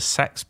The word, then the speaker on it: CEX: We were told repeatedly to enforce the sex pronunciation, and our managers ran with it as far as they could.